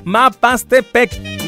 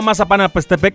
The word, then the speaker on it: Mapastepec: Ahora sí lo dije bien, Mapastepec. No Mazapanapastepec. No.